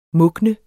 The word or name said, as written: mugne